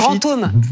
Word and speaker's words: Brantôme: Brantôme.